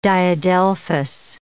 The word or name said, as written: diadelphous